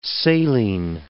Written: saline

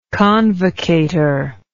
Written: convocator

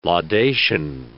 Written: laudation

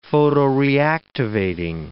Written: photoreactivating

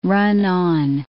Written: run-on

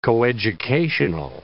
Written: coeducational

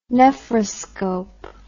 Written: nephroscope

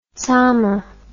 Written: tsamma

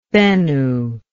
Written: Bennu

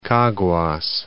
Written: Caguas

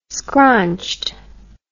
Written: scronched

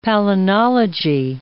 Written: palynology

